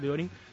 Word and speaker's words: Theorin: El siguiente sueco se llama Johan Deorin. Se escribe T-H-E-O-R-I-N. Ese es el apellido, Deorin. Se pronuncia Deorin.